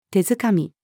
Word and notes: 手づかみ: female

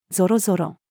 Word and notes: ぞろぞろ: female

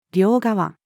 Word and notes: 両側: female

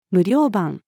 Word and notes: 無料版: female

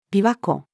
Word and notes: 琵琶湖: female

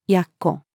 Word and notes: 奴: female

